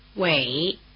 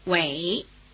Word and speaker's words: Wei: Wei, Wei, Wei. – The diphthong A is spelled E-I, as in Taipei, the largest city in Taiwan, which is pronounced in Chinese like this.